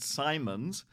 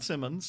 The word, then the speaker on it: Simonds: This is Brenna Kate Simons. Not Simmons, Simons. It rhymes with diamonds.